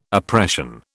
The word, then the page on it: oppression: British English